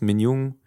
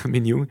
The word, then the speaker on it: Min Jung: die Frau heißt Min Jung, die südkoreanische Aussprache, nicht die norddeutsche Aussprache, Min Jung.